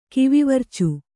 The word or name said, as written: ಕಿವಿವರ್ಚು